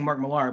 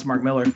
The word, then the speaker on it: Millar: I'm so used to saying Mark Millar, but apparently it's pronounced Mark Miller.